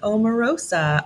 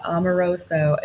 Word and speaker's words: Amoruso: And Amoruso, is that how you say it? – It's Amoruso. People just mix up all of the vowels. So it's like Amorosa, Amoruso. It's just, it's whatever you want it to be, but it's phonetic.